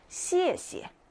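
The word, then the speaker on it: 씨에, 씨에 (谢谢): 그래서 첫 번째 건 세게, 두 번째 건 짧고 가볍게 읽는 거죠. 씨에, 씨에. 씨에, 씨에. 이게 중국어로 감사합니다라는 뜻입니다.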